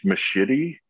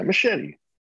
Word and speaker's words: Machete: I think it's Machete. No, I got white as shit. Machete. – Yeah, Machete.